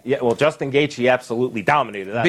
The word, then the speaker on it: Gaethje: It's just like I pronounced on UFC the other day, Justin Gaethje, when it's Justin Gaethje.